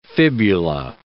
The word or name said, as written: fibula